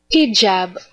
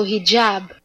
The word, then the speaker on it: hijab: Hijab, also hijab.